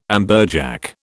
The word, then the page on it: amberjack: British English